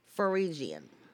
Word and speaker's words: Phrygian: I, when I looked it up earlier, it was pronounced with an F, Phrygian.